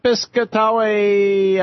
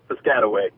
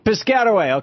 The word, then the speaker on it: Piscataway: Piscataway, I'm sure I mispronounced that, New Jersey. Hey, how do you say it? – Hey, how's it going? It's Piscataway. – Piscataway, okay, I've heard that before.